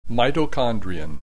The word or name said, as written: mitochondrion